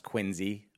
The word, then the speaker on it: Quincy: So I remember when we used to live together here in Quincy, it's pronounced Quincy, like with a Z.